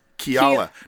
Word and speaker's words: Keala: I don't know how to pronounce devil. It. It's spelled K-E-A-L-A. Kiala. – Kiala.